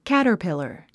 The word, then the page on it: caterpillar: kǽtə r pìlə r; キャタピラァ